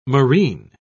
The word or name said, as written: moreen